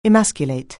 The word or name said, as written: emasculate